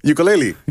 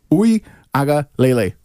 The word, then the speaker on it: Uiagalelei: Ukulele. – Uiagalelei.